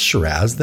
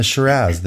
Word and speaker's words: Shiraz: We have got a Penfolds Kununga Hills Shiraz for, is it pronounced Shiraz there? – I believe it is. – A Shiraz then. – It's like Canadian. – A? – Shiraz, taco, garage.